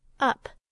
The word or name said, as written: up